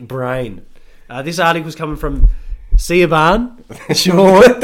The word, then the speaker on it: Siobhan: Anyway, it's spelled Siobhan, pronounced Siobhan.